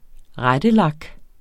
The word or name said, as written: rettelak